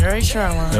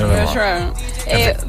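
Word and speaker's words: Rae Sremmurd: Pero lo pronuncia bien, Ray Sremmurd. – Ray Sremmurd.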